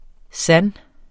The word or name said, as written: sans